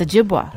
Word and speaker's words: Ojibwa: Is it Ojibwa or It's Ojibwa. – Ojibwa.